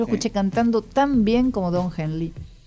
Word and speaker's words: Don Henley: para ver que no solamente los documentalistas abordan esto, hay canciones que lo hacen, como la de Don Henley, no sé si lo estoy pronunciando bien. – Don Henley, sí.